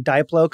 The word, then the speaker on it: Diplo: Bunch of halon gas shoots down like a fucking Diplo concert, like just freezes it out in your special Teflon bags. – Have I been mispronouncing Diplo? – No.